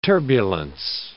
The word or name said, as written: turbulence